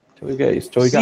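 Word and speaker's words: shoegaze: ¿Cómo se pronuncia? ¿Choygaze? – Choygaze.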